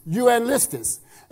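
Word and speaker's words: euangelistis (εὐαγγελιστής): Well, one of the ways I can know it is because that word evangelist there in the Greek, it is pronounced euangelistis.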